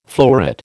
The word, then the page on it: fleuret: American English